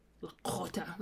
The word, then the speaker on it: Lakota: So she's Lakota. Lakota. That's how they say it.